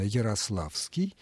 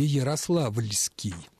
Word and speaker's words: Ярославский: Другими словами, как правильно «Ярославский» и... или «Ярославльский», «Рославский» или «Рославльский»? Правильны первые варианты. Суффикс «ли» исчезает.